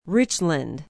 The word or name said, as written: richland